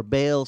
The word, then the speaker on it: Baal: Now Baal, or Baal, some people pronounce it in English,